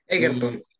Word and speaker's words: Egerton: Egerton, Taron eh, Egerton.